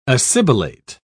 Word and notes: assibilate: British English

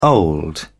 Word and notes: old: British English

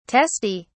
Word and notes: teste: British English

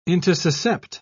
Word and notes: intussuscept: British English

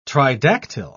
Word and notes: tridactyl: British English

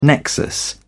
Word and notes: nexus: British English

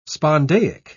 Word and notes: spondaic: British English

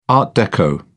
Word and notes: art deco: British English

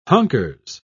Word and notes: hunkers: British English

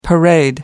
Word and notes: parade: American English

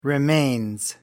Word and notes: remains: American English

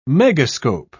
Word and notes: megascope: British English

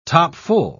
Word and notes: topfull: British English

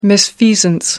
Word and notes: misfeasance: American English

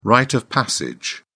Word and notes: rite of passage: British English

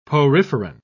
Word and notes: poriferan: British English

